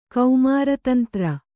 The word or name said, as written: ಕೌಮಾರತಂತ್ರ